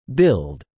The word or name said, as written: billed